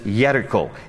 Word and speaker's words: Jericho: The word Jericho in Hebrew is actually spelled with a Y. There's no J in the Hebrew alphabet. It is Jericho.